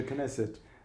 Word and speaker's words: Knesset: We've talked Israeli elections, and I've learned a bit about the new members of the Knesset. Have I pronounced that right? – Yeah, absolutely, the Knesset.